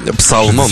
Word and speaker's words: псалмом: По словам представителя РПЦ, число 67 связано с 67-м псалмом. – 67, да. Да. – Или псалмом, правильно, как ударение из Библии.